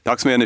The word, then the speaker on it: Dagsmejan: Daxmian ist ein schwedischer Name.